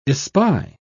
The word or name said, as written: espy